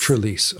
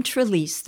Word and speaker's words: Trelease: Trelease, okay. – Yeah, Jim Trelease, the Read Aloud Handbook.